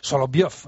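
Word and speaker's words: Soloviev (Соловьёв): Algunos están Soloviev, así es, esa E es un poco cerrada.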